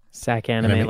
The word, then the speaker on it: Sac Anime: Sac Anime, like Sacramento.